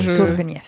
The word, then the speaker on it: Tourgueniev: Je pense que c'est Niev. – Niev, ouais. – Tourgueniev. – Tourgueniev. – C'est ça.